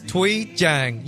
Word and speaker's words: Thuy Trang: It's Thuy Trang.